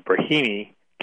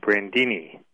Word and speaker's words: Brahimi: Last week, John Kerry began mispronouncing the name of the U.N. envoy in Iraq. His name's Brahimi. Kerry was calling him Brandini, an unusual mistake for Kerry to make.